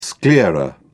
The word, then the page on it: sclera: British English